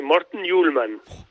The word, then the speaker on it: Morten Hjulmand: Bueno, lo dijiste bien, ¿eh? Morten Hjulmand.